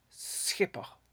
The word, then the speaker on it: schipper: That comes from a word that the English find it very difficult to pronounce, the Dutch word schipper. To pronounce the Dutch, begin with an S and then do a velar fricative, or pretend you have a throat disease.